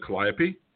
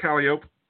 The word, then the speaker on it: calliope: But is it calliope? No, it's calliope.